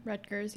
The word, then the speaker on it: Rutgers: Rutgers, yep.